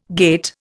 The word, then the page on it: gait: Indian